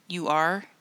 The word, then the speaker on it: Ur: And so one of the excavations that they were at was of Ur, U-R. Mm-hmm. It looks like Ur, but it's pronounced Ur.